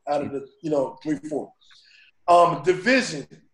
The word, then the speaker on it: DVSN: Yeah, Division, but it's spelled D-V-S-N.